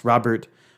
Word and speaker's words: Rodbert: St. Rupert, or Rodbert, pronounced Robert,